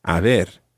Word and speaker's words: haber: And that different verb in Spanish is haber. And that's spelled H-A-B-E-R.